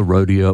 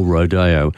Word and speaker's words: rodeo: Both pronunciations are listed by the Oxford as correct and acceptable. So you can say, as far as the big boffins at the Oxford are concerned, either rodeo or rodeo.